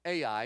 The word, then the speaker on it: Ai: It's just simply spelled the letters A and the letter I. Ai. Ai. It's pronounced just like it looks.